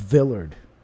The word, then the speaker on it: Villard: Okay, so now we have this Bob Villard. I think that's how you say his name. Villard. V-I-L-L-A-R-D. Bob Villard. Bob Villard was a... What's that? – Sounds about right, I would say.